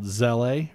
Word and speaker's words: Zelle: It's called Zelle or Z-E-L-L-E.